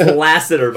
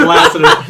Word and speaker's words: Lasseter: It's Lasseter, by the way. – Yeah, it's Lasseter. John Lasseter. – Lasseter. – Lasseter? Did you say Lasseter? – I might have. – Yeah, Lasseter.